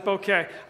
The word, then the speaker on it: bokeh: At least according to Webster's Dictionary, because they online they give a nice little pronunciation, which I checked, and it was bokeh.